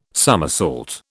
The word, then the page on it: summersault: British English